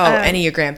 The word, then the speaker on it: Enneagram: I just was like, I don't want to do the anagram. I don't even think I'm saying it right. – But like, also, that's probably, I bet if you took what, like, the Myers-Briggs or the, yeah, I'd rather hear how you say it right. Enneagram, whatever. – Oh, Enneagram. That's it. Thank you.